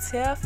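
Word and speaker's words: Typh: You can holler at me at Acuity and schedule a Get a Grip with Tiff.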